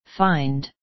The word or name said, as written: find